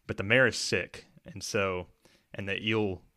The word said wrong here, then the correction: Elu: It's pronounced Elu.